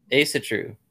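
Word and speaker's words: Ásatrú: I actually found quite a few articles on Asir, is that how you pronounce that? It's the Asir. – Yeah. No. I'm sorry. Ashru. – Asatru. – Yes, Asatru.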